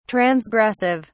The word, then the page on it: transgressive: British English